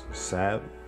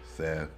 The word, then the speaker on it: Sab: And my name is Sab.